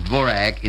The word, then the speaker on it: Dvořák: Antonín Dvořák, who is pronounced Dvorak in America, and those of Rudolf Rimmel.